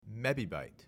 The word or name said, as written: mebibyte